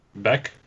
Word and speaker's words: Beck: At one really point, at the end, when one of is it Vec? Is that how you say his name? Vec. – Beck. – Beck, with a B or V? – B-E-C-K. – It's a B.